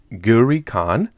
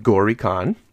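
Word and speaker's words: Gauri Khan: And I think I'm pronouncing that correctly. Ghuri Khan. Let's hear. – Khan. – Oh. – Ghuri Khan. – Ghuri. See? Sometimes I can find a way to pronounce it for me.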